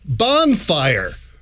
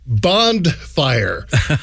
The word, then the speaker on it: bonfire: Another word I've been pronouncing until my mid-30s, bonfire. B-O-N-F-I-R-E. – Sure. – My entire life, I always heard the word as bondfire. B-O-N-D-F-I-R-E.